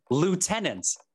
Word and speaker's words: lieutenant: All right, British people, there's no F in the word lieutenant. There's no F. I know it's weird that it's spelled Lou, L-I-E-U, Lou in Lou, and then you use the Lou, which is L-O-O.